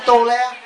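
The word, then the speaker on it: tôle: Tấm tôn á, hồi xưa cái tôn của Việt Nam Cộng Hòa mình là chỉ tôn là không, chữ T chứ O L E thì nó đọc là cái tô le đẩy cho cái ri ke.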